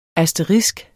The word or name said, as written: ASTERISKENS